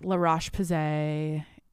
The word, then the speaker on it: La Roche-Posay: And I do a lot of high and low skincare where it's like Cetaphil and then and like La Roche-Posay is how you say it, mixed with like my higher end shit.